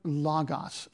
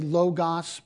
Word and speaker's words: logos: This is the Greek word logos. Some say logos, but the pronunciation would properly be logos.